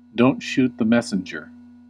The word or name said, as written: don't shoot the messenger